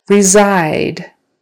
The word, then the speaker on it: reside: Reside. Reside. They reside in California. Reside. The stress is on the second syllable.